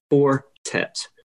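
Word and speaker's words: Four Tet: F-O-U-R, next word, T-E-T, Fortet.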